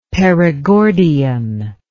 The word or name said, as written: Perigordian